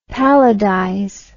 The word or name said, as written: palladize